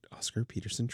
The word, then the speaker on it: Oscar Peterson: Before that, you heard Lester Young with the Oscarson Peter Trio. I feel like I didn't say that right. Oscar Peterson Trio.